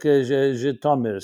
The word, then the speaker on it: Zhytomyr: Anyone that, cities like Zhytomyr, cities like Kharkov. I'm pronouncing it in the Yiddish and the Jewish way. I know their names have been somewhat changed pronunciation, but we're all familiar with them.